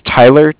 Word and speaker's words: Tyler: Yeah, my name is Tyler, T-Y-L-E-R, and I'm calling with Cardiff. It's spelled C-A-R. D-I-F-F,